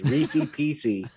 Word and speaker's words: Reese's Pieces: Reese's Pieces. – you left terrible. Off the S. Terrible. Both S's. Reese's Pieces.